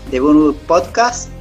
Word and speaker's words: The BurnBook Podcast: Bueno, Lorna es eh, integrante, fundadora de The Boonwood Podcast.